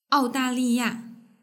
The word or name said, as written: aodaliya